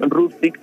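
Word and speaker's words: Hrustić: Se escribe H. Rustic. Rustic, ¿viste? Rustic, no, como rústico.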